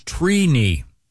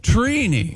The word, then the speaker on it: Trini: The correct pronunciation is Trini. – Wow. – Trini. – Like T-R-E-E. – Like weenie. – Yeah, I is it phonetically T-R-E-E-K-N-I? – Yeah, it's, phonetically it is T-R-E-E.